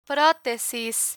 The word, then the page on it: protesis: Spanish